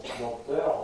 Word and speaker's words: dompteur: Euh, vous écrivez donc, donc, dompté avec un P, et hein. vous prononcez et vous vous demandez pourquoi. Euh, c'est fini, on dit donc dompteur, on entend dompteur, mais, euh, quand j'étais jeune, on a entendu, il fallait dire dompteur.